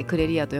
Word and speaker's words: CREREA: CREREA と書いてクレリアと読むんですけ